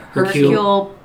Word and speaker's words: Hercule: Hercule Poirot.